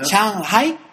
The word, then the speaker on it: Shanghai: Shanghai.